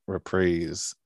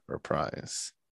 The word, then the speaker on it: reprise: But yeah, it's actually pronounced reprise and not reprise.